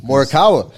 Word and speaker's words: Morikawa: Wait, can you say his name one more time? – Morikawa. – Oh, he's got it.